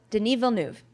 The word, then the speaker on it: Denis Villeneuve: Meanwhile, I asked Eric Heiserer, who wrote Arrival, I'm like, how do you say it? And he goes, Denis Villeneuve. So I'm just going to stick to that now on.